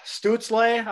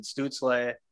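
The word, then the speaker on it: Stützle: Yeah, it's Stutzle. I think I've been listening to a few Ottawa games. I think that's how you actually pronounce it, Stutzle.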